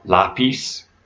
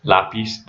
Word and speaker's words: lápiz: So this could be something like información, like the O just before the N sound, información, or lápiz, lápiz, there's an accent on the A in the first syllable.